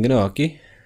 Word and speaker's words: gnocchi: You mean gnocchi? – Gnocchi. Right, that's what I said. – Gnocchi. – Right, rhymes with low-key. Like, keep things gnocchi. – Yeah. – Keep things low-key.